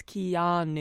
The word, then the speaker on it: Keanu: It's Keanu.